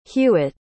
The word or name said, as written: huette